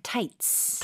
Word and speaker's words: tights: 영어로는 츠 발음으로 절대로 하시면 안 되고요. 그냥 tights.